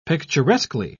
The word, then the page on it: picturesquely: British English